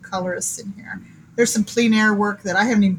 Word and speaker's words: plein air: It's plein air.